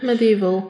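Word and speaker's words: MediEvil: Medieval? Tak się to wymawia? – Medieval.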